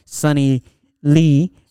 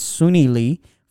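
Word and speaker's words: Suni Lee: I want to congratulate the girl Sunny Lee Sunny Lee. – Oh, okay. Sorry, I pronounced it different. It's Sunny Lee for winning the Olympic gold medal.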